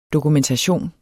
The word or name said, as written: dokumentation